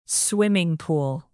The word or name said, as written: swimming pool